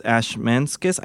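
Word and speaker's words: Ashmanskas: So also in The Prom is Brooks Ashmanskis. I never know how to say his last name. – I think that's right.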